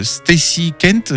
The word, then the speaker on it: Stacy Kent: Alors, un titre de Stacy Kent, j'espère que je prononce bien.